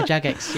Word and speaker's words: Jagex: Jagex? Am I saying that right? It looks like it. – Yeah, you are, actually. Yeah, you are Jagex.